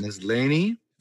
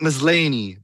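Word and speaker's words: Maslany: How do I pronounce that last name? – Facts. – Ms. Laney. – Facts. – Tat Ms. Laney. That's how she has it in her bio. So that's how I'm going to pronounce her name.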